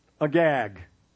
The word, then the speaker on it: Agag: Samuel says, Bring me a gag. That's how it's pronounced.